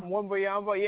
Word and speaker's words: Wembanyama: Is that how you pronounce his name? Wembanyama? Wembanyama? – Wembanyama.